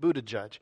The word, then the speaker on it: Buttigieg: I saw a little a Facebook clip where our Secretary of Transportation, Pete Buttigieg, I think that's how you pronounce his name. That's not how it looks, but it's Pete Buttigieg.